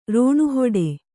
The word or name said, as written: ರಣಹೊಡೆ